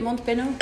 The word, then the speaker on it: Mont Benoît: Is that how you say it? Mont Benoît? Yeah, Mont Benoît.